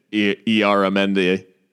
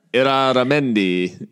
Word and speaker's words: Illarramendi: I mostly dislike David Moyes for his uh, butchered pronunciation of Asier Illarramendi. Uh, Iraramendi, if I yeah, that's that's Moyes.